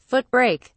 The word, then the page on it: foot brake: British English